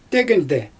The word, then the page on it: tygynde: Xyzyl